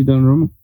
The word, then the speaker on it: Donnarumma: And Donnarumma, how do you say his name? – Gigi Donnarumma.